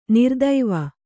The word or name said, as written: ನರತ್ವ